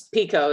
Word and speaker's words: Picoult: It's Picoult, so it looks like it would sound Picoult. But it's Pico is how she pronounces it.